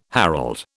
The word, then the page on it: herald: British English